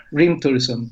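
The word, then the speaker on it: Hrímþursum: I, I probably the Icelandic people should uh, pronounce it, but uh, that's uh, skip the H in the, in the beginning and just focus on the R. Hrimthursum.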